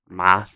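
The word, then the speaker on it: Maß: Mass. Is that how you pronounce that? – Yeah. That's yeah, it's cool. Got the double S/ thing that looks like a beta, but isn't a beta in German.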